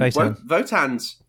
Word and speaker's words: Wotan: And Wotan. It's spelt the W. They do it the German way.